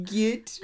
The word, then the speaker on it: jit: No, it's pronounced git, of course. The J is a G in this case, guys.